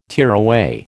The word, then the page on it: tearaway: American English